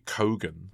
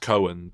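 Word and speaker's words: Keoghan: Apparently it's Barry Cogan. – Cogan, right, okay. – Apparently it's often pronounced Cohen, but he goes Cogan with, like, specifically.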